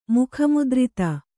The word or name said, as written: ಮುಖಮುದ್ರಿತ